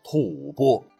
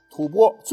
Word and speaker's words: 吐蕃: 而这个令大唐寝食难安的政权便是吐蕃。这个蕃是草字头底下一个番茄的番，很多朋友会错念成吐蕃。实载这个吐蕃很可能来源于久居我国境内最为古老的民族之一羌族，就是羌族进入西藏统一各部所形成的。吐蕃最高统治者为世袭赞普，说起来这也是一个部区的民族啊。